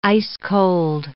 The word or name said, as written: ice-cold